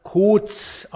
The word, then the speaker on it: Koz: und deren Familienname spricht sich im Original Kotz aus, also K-O-Z.